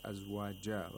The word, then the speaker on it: أزواجا: But for the second أزواجا, we pronounce it like azwaja, like an alif ulma, because we're stopping on the word which has tanwin al fatha.